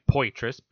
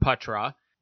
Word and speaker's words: Poitras: because on paper it looks like Poitras, P-O-I-T-R-A-S, Poitras, and when I was listening to play-by-play clips with him in them, I heard Patra, but I know the last name as Poitras, very French.